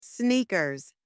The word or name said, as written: sneakers